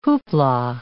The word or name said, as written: whoopla